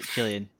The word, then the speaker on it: Cillian: The actors, I forget how to say his name, Cillian or Killian Murphy? – Killian.